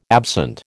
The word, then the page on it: absent: American English